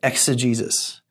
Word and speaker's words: exegesis: And to throw a fancy word at you, to read it for what it means is the fancy word exegesis. And just because you hear the word Jesus in the word, it's not spelled anything like our Lord and Savior's name. It's spelled E-X-E-G-E-S-I-S. And the word exegesis literally means to extract from.